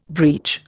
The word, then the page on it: breach: Indian